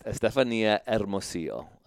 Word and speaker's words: Estefania Hermosillo: My guest, Estefania Hermosillo, an awesome Latino name that I just got to pronounce and I felt really good about it.